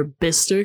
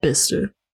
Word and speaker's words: Bicester: I remember there was a moment I asked in the Max Mosley documentary where Bister came up, and it's spelled B-I-C-E-S-T-E-R, and it looks like you're supposed to say Bicester or Bicester or Bicester or whatever, but it's just Bister.